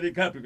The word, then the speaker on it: DiCaprio: DiCaprio, Cabrio, man. Pues enséñale a que le fue que lo pronunció mal. Mira, Bruto, que es DiCaprio que se dice.